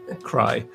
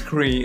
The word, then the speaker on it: Cree: And first and foremost, I want to say that good that you talked about it before me because I was about to pronounce them. Cry. Now I know it's Cree.